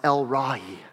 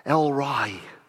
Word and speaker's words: El Roi: In fact, if we go back to the Old Testament, I love this, one of the names of God that we see, E-L-R-O-I, it's pronounced El-Rai, El-Rai, it means the God who sees.